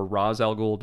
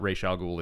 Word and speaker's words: Ra's al Ghul: Actually, funny enough, my comment on Nostromos is we're currently in our order, in production order, we're pre-Ra's al Ghul. – Yeah. – Or Ra's al Ghul, depending on the pronunciation. This guy is almost like a parody of what Ra's al Ghul is like on the show.